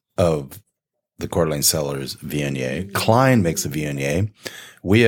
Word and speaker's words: Viognier: Viognier, by the way, is spelled V-I-O-G-N-I-E-R.